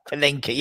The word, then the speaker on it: Panenka: the pink Pinelka. – Yeah, the Panenka, yeah, the loopy one.